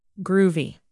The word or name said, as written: groovy